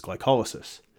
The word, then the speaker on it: glycolysis: Some people do pronounce it glycolysis, but I believe the correct pronunciation is glycolysis.